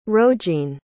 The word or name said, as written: rogene